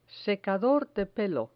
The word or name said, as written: secador de pelo